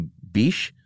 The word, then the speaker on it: Bic: In 1945, a Frenchman named Marcel, would it be Biche? B-I-C-H? – I think it's Bic. – Is it Bic? – That's why he dropped the H's so people could pronounce it.